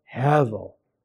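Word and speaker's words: Hevel (הבל): It sounds like the word heavy. By the way, that's an onomatopoeic word. It means a word that it sounds the way it's said. It's heavy. Hevel.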